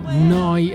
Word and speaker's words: Neu: noi Radio, si scrive Neu, si pronuncia Noi e vuol dire nuova, nuova emittente urbana, neuradio.it, dal sito della dalla pratica app in diretta.